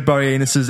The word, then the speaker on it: Boreanaz: How did you say that, his name? – Boreanaz. What's his name? – Boreanaz. – Boreanaz. – I said Boreanaz, David Boreanaz. – It's not anus.